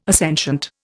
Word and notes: assentient: Indian

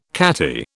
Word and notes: catty: British English

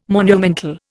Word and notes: monumental: Indian

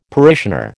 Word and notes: parishioner: American English

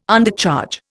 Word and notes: Undercharge: Indian

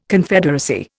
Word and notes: Confederacy: Indian

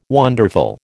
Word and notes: wonderful: American English

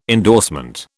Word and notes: endorsement: British English

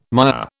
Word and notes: mire: British English

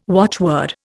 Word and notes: watchword: Indian

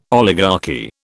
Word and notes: oligarchy: British English

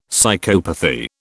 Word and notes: psychopathy: British English

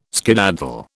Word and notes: skedaddle: British English

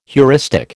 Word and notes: heuristic: American English

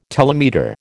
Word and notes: telemeter: American English

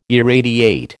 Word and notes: irradiate: American English

